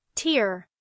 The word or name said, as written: tear